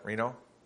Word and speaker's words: Reno: Is that Reno? Like the city? Reno? Okay, very good.